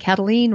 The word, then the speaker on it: Kataline: It's all under my name, Karen Cataline. Spelled the normal way, Cataline, spelled K-A-T-A-L-I-N-E, rhymes with spleen. Cataline rhymes with spleen.